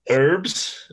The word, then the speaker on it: herbs: The fact that some people, it seems, don't say the H in herbs just really confuses me. – There is an H in it, isn't there? Yeah. But it's not even, yeah, and it's not even a question of being a silent H.